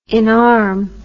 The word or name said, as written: inarm